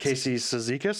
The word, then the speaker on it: Cizikas: Matt Martin, Casey how do you say that? – Casey Cizikas. – Cizikas and yeah.